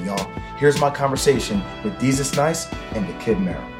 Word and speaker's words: Desus: It's Desus!